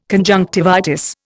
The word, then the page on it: conjunctivitis: Indian